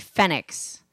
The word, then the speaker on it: Phenix: In English, phonetically, P-H-E-N-I-X would be Phoenix.